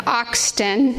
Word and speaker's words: Austin: And she's going to be introducing Jessica Austin. It rhymes with exhaustion.